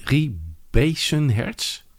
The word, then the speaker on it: Rie Beisenhertz: Ja, de eerste kampioenen op de 100 vrij waren Co Korsten en Rie Beisenhertz. Zeg ik dat goed? Ja, Rie Beisenhertz was een gemster uit Amsterdam.